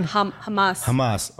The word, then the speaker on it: Hamas: Hamas.